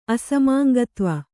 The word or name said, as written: ಅಸಮಾಂಗತ್ವ